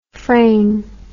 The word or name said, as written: frayn